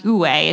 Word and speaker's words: Uwe: Another son, I believe his name is pronounced Uwe, it's U W E.